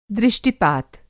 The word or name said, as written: दृष्टि-पात